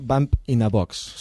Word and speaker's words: Bump in a Box: Només corregir, és que m'he equivocat, és Bump in a Box.